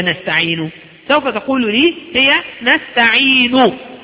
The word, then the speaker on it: نستعين: نقوم بضم الشفتين بعد الانتهاء من الكلمة والوقوف عليها بالسكون، يعني أنت ستنطق كلمة نستعين وتقف عليها بالسكون ثم تقوم بضم الشفتين تقول نستعين، أنا قلت نستعين، وبعد ما انتهيت من تسكين النون مباشرة ضممت شفتي إلى الأمام، ضممت شفتي إلى الأمام نستعين، فأنت سماعا لن ترى فرقا، لكن رؤية سوف ترى شفتاي قد مدتا إلى الأمام، وهذا هو الإشمام.